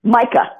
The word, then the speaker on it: Micaiah: Tell us about Miracle for Micaiah. – So the illustrator on that book. – Am I saying that right? Is it Micaiah or Micaiah? – It's Micah. – Micah. Okay, there you go.